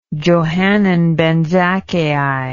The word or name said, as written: johanan ben zakkai